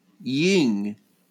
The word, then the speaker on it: Jung: And I actually looked up how to pronounce the name. It's Ying. Ying.